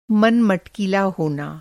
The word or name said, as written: मटकीला